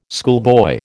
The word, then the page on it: schoolboy: American English